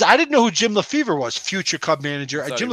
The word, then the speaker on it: LeFevre: So Jerry and John got traded to the Yankees for Rupert Jones, Joe LeFevre, not Jim, not his brother Jim, Joe LeFevre. – Oh, I believe was that the guy that played for the Phillies? Yeah, he pronounced it LeFay.